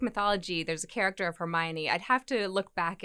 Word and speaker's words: Hermione: Maybe you could tell me, but Hermione, mm-hmm.